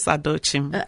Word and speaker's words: Adochim: And today we have a special guest in our studio, and I would like to welcome Gladys Adochim, and I bet I didn't say it right that did I time, did I? – You're fine. Gladys Adochim. – Adochim, yes. Adochim. Boy, I was really far off.